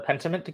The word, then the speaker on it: Pentiment: The Pentiment game.